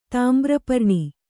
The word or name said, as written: ತಾಂಬ್ರಪರ್ಣಿ